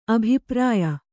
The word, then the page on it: ಅಭಿಪ್ರಾಯ: abhiprāya